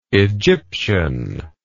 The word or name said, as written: Egypticity